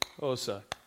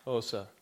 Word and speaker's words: Xhosa: If you say it correctly, it's Xhosa, Xhosa. Click your tongue off the roof of your mouth. Try that. It's really hard. Xhosa.